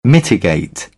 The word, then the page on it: mitigate: British English